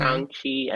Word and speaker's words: Shang-Chi: I'm more invested in this movie because of Shang-Chi. Is that how you say it? Mm-hmm. Shang-Chi and the Seven Rings.